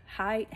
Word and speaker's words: Haidt: Jonathan, I think his last name's Height. Height is spelled weird, but I think it's Height, H-A-I-D-T maybe.